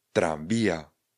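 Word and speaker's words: tranvía: Tramvaj se vysloví trambia, trambia, trambia. Ano, ono to je něco mezi M a N.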